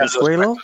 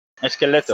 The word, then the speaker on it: esqueleto: Escuelo? How do you say skeleton? Is that correct? – Esqueleto.